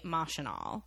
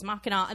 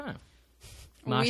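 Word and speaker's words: Machinal: I guess the writer intended it to be Mosh and All, based on the French for machine. But when it opened on Broadway in the 20s, people referred to it as Mackinac. I mean, so it has this very controversial history over how you pronounce it, as far as I'm concerned.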